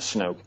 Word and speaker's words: Snook: And the other person is a Napa County deputy sheriff named Hal Snoke, you could pronounce it Snook. It's spelled S-N-O-O-K. As far as I know, the correct pronunciation is Snoke.